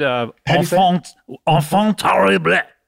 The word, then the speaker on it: enfant terrible: Like infant terrible or whatever it is, you know, just like a nightmare human being. And we pronounce it enfant terrible.